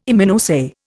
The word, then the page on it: Immunoassay: Indian